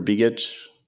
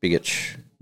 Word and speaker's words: Begich: I'm going to butcher his last name, but it's Nick Begich or Begich. – Begich. – B-E-G-I-C-H.